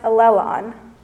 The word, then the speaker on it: allelon: In Greek, this term is pronounced allelon.